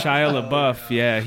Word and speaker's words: Shia LaBeouf: Shia LaBeouf, how do you say his name? – Yeah, Shia LaBeouf,